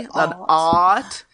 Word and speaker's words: Att: Especially if it's spelled the same, like an A-T-T, yeah, and I would O-T-T, say, and it's just pronounced differently. – It's just pronounced differently. So Mary Ott, it's actually A-T-T, but it's a long A, an Ott. And then in Shuttle Bay 2 is Lieutenant High At, and it's spelled the same way, but she pronounces her last name with the short A, At.